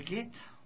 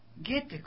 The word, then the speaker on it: Goethe: sobre el aniversario de la muerte de Goethe, o Goethe, como también lo pronuncian otras personas o lo llaman, ¿no?